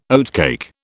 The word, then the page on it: oatcake: British English